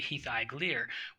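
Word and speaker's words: Hithaeglir: And it was supposed to be Hithaiglir, which instead of Hithaglen, it was Hithaiglir, which is A-E, because A-E is pronounced I, and A-I is pronounced A.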